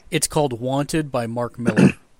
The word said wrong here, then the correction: Millar: It's okay. Millar, M-I-L-L-A-R. – Why do you say it like that? – I did. – She said Miller. – No, Mark Millar. – Millar. – Yep. – Millar. Okay, Millar. Got it.